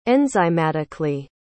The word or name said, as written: enzymatically